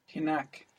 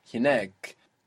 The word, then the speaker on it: hinak: Hinak. Do we have a strong sound here? – Yeah, we have two vowels together. – Yeah, we have a long vowel, effectively. Hinak. And that attracts the accent. Hinak. So we see the accent there move between hina and hinak because of this strong sound, which pulls the accent onto the last syllable.